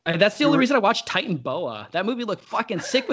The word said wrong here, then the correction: Titanoboa: You mean Titanoboa or Titan Boa? – No, the first one. I said it wrong.